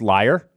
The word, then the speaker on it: Leier: Actually, if you look at my name, it's pronounced Liar. – Yeah. – L-E-I-E-R, like Meyer, Fryer.